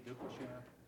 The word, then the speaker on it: Duplechain: Randy Duplashan, 23 North Walnut Street, Milford, Delaware. – Spell that, please. – D-U-P-L-E-C-H-A-I-N.